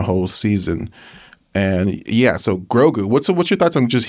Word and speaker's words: Grogu: Yeah. Grogu. Yeah, Grogu. You said it right the first time.